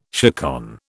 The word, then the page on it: chaconne: British English